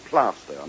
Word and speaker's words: Plaistow: Yes, but it's called Plaistow, and I know that.